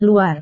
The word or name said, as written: luar